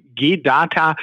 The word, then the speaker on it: G DATA: Also äh, du lagst mit der ersten Aussprache G-Data vollkommen richtig.